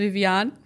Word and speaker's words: Vivian: Ich heiße Vivian mit A. – Ach so, ich habe es auch falsch ausgesprochen.